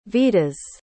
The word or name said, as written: vitas